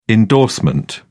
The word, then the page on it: indorsement: British English